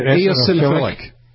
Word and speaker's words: Eosinophilic: Eosinophilic.